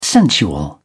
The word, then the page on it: sensual: British English